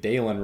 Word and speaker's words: Dylan: So, if you're looking for silver linings, they did find a new, certainly at worst, a new number two receiver, and they were pronouncing it Dalen Wright on the broadcast. It is spelled like your run-of-the-mill Dylan, but if Dalen is what it is, Dalen is what it is, the transfer from Texas A&M.